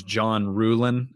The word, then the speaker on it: John Ruhlin: Uh, his name is John Ruhlin.